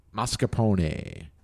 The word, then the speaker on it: mascarpone: mascarpone.